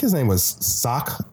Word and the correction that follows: Sako: Sako. – Villager. His name was Sako.